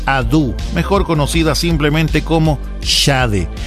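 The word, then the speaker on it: Sade: Créame que en lo personal he escuchado muchísimas maneras de pronunciar este nombre, pero tuvimos que buscar en internet un registro visual donde la propia Shade nos entregara la forma de pronunciar su nombre artístico. Así que según la propia Shade, se pronuncia así su nombre.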